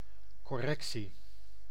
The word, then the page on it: correction: [kə.ˈɹɛk.ʃən]; American English